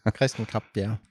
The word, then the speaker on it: Kresten Krab-Bjerre: Kresten Krab-Bjerre.